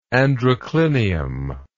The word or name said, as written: androclinium